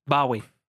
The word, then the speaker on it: Bowie: Porque en realidad Bowie es un cuchillo. El Bowie knife. Eh, eh, es Bowie, se pronuncia. Pero Bowie, siendo británico, no tenía idea, vio la palabra y dijo, mm, es interesante, me voy a poner de nombre. Quedó como nombre y él siempre lo pronunció mal.